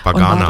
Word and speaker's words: Backnang: Bagana dort, spricht man. Ne? – Nochmal? – Bagana spricht man, oder Bagana. – Bagana. Ja, ich weiß nicht, wie sie ausspricht, aber es heißt Backnang, ja.